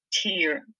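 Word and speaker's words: tír: So T-I-R with a long I. So tear, that word is country.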